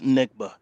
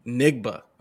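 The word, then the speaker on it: Njigba: Jackson Smith Nigba. – Nigba.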